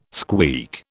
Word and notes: squeak: British English

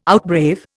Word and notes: outbrave: Indian

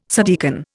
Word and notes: subdeacon: Indian

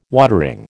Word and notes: watering: American English

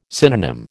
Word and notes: synonym: American English

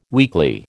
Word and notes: weekly: American English

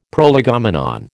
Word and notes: prolegomenon: American English

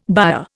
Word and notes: byre: Indian

